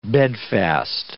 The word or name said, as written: bedfast